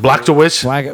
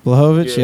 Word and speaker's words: Blachowicz: Blachowicz? – Jean Blachowicz? – Blachowicz. – Polish power. – Blachowicz. Yeah, there you go. I want to say Blachowicz like from like Wolfenstein, Castle Wolfenstein, but nah, it's Blachowicz.